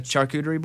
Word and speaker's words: charcuterie: A charcuterie board? – Yeah. – Which I don't say it right either. – Charcuterie.